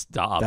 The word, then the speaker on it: Dobbs: Is it Dubs or Dobbs? – It's Dobbs.